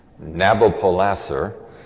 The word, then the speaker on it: Nabopolassar: Nabopolassar, that's the way I've learned to pronounce it anyway, was an old man.